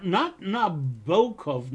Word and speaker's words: Nabokov: Not Nabokov, as he said, rhymes with knockoff.